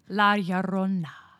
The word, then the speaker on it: La Llorona: This is the legend of La Llorona. – You didn't roll the R, but I guess everything else is okay.